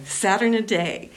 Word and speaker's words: Saturniidae: Saturniidae.